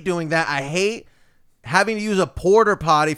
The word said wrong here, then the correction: porta potty: I'm saying it wrong. Porta? Is it porta potty? – Yeah, yeah.